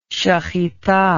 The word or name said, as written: shehitah